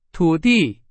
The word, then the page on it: 土地: Chinese